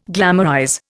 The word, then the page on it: glamorize: Indian